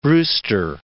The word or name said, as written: Brewster